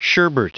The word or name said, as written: sherbert